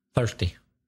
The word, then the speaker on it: thirsty: I am, feel thirsty. I am or feel thirsty. – Be careful of the pronunciation of thirsty. That's often mispronounced by Spanish speakers. Thirsty. I'm thirsty.